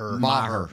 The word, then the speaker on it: Maher: Maher.